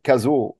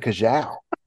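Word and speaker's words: Cazaux: I'm really looking forward to seeing more of the young Frenchman Arthur Cazot, or as Rene Stubbs pronounced his name in the ESPN coverage, Arthur Cajal.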